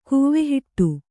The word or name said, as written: ಕೂವೆಹಿಟ್ಟು